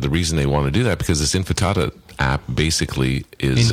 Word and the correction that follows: Intifada: Intifada. – Intifada, is that how you pronounce it? – Yeah, the T's before the F.